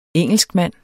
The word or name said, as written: engelskmand